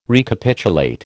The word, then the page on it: Recapitulate: American English